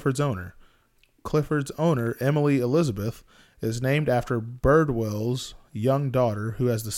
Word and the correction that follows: Bridwell: Oh, I was saying his name wrong. It's Bridwell. Was originally going to be named Clifford...